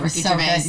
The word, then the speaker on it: Gervais: Ricky Chavez? Or Ricky Gervais. – Oh, Ricky Gervais. – Did you? Oh, yeah, I said it wrong.